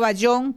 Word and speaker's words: Bayón: Estuvo por los lados de Arauquita y luego a Arauca, precisamente, y por el apellido de él, de Bajeón, o Bayón, como se pronuncia, pues está en homenaje a él, está el reconocimiento de ese brazo del río Arauca que se llama Bayonero.